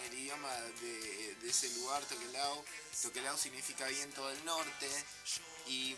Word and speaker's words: Tokelau: amigos, estamos acá ya con los chicos de Tokelau. ¿Cómo se pronuncia? A ver, decime porque Tokelau.